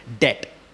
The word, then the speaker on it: debt: Okay, debt. It's not debt. Okay, my wife corrected me before I came here. It's debt. Okay, the B is silent.